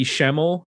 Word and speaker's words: Schemmel: They left and were replaced by Patty Schemmel. Is that how I'm pronouncing it right? Schemmel? Yep. On drums.